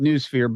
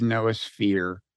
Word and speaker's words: noosphere: So it comes from the Greek, noos, N-O-O-S, although in Greek, apparently it's pronounced noos. And some people pronounce it noosphere, but I think the official online automated pronunciation is noosphere.